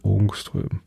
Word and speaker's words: Ångström: Ich glaube, das A mit dem Kringel oben drauf wird O ausgesprochen. Ångström. So, ähm, ein Ångström sind 0,000000001 Meter, also 10 hoch minus 10.